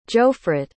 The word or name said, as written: johfrit